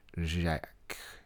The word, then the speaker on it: Jacques: I would say Jacques. It's J-A-C-Q-U-E-S.